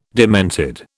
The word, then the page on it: demented: British English